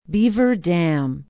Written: beaver dam

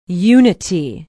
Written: unity

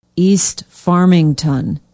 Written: east farmington

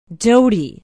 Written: doty

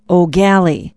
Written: eau galle

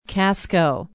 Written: casco

